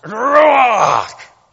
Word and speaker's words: ruach: It is, in English, when you're reading along, it's pronounced ruach. But when it would be read in the Hebrew at the time, in the Old Testament, it would be pronounced ruach. That's how it's pronounced.